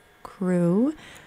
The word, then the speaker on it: CHRU: WXYC would like you to know about the Carolina Harm Reduction Union, known as CHRU, pronounced CRU.